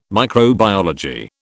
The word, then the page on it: microbiology: British English